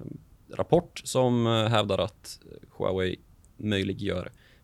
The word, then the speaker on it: Huawei: Eh, lite kort kan vi göra Huawei. Jag har alltid ja. – velat eh, att folk ska höra mitt uttal. Huawei.